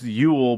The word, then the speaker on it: Joel: It's pronounced Yule, but it's spelled Joel. Yule Eriksson Ek. – Let's see how Doc takes that one. – It's going to be Joel Eriksson, probably.